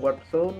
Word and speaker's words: Warpzone: escribe Alan WARP Zone con Z. De zona.